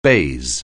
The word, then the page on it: baize: anglais